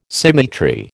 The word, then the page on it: symmetry: American English